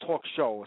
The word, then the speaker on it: Talk Shoe: It's called Talk Shoe. Like a shoe you wear on your foot, you spell it? – Right. – but it's pronounced talk show.